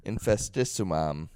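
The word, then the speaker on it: Infestissumam: When Infestificism came out, it's their second album. I can't fucking say it. How would you say it? – Infestissimum. – Yeah, what he said came out.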